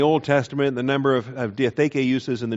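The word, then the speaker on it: diatheke (διαθήκη): The Greek is diatheke, D-I-A-T-H-E-K-E, the long E's on the end of that, the aetis, diatheke, number 1242 in the Strong's Concordance, and only 33 uses.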